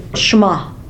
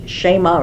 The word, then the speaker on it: Shema: Now in Hebrew, the E is pronounced like a long A, so it's Shema.